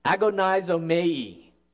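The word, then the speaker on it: agonizomai (ἀγωνίζομαι): But the word here in the original Greek is, see if I can say it right, agonizomai. And this is where we get our English word agony.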